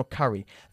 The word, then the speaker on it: curry: The curry, no se dice curry, sino curry.